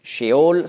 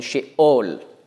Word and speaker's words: Scheol: Nicht Sheol, sondern Sheol. Mit Stimmabsatz.